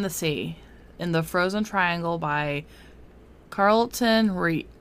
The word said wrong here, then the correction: Rede: Rede. – For those who are wondering why we're having issues, it's spelled R-E-D-E.